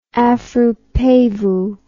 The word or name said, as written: afropavo